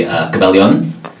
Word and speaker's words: Cabellon: I've been told that the Filipino Tagalog pronunciation would be Cabellon. – Oh. – But I'm not going to make casting directors do that. – Yeah. – I just go as phonetic as possible.